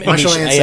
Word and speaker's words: Michel Ancel: M- M- Michel Ancel.